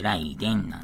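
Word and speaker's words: 雷電: 雷の電気と書いて、ライデンなんですけどね、チームライデン。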